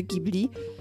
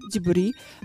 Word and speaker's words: Ghibli: E, I pamiętam, że byłam w totalnym szoku, e, bo to GH, wydawało mi się, że to jest może jakiś amerykański zapis niemego G, że to jest naprawdę Ghibli, E, bo przecież jest taka zgłoska gi w japońskim. E, I pamiętam, że doznałam szoku, że to, że to się naprawdę wymawia Ghibli,